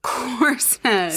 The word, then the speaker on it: Corsned: Corsned. – C-O-R-S-N-E-D.